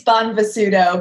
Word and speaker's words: Bonvisuto: It's Bonvisuto, but it's a long one.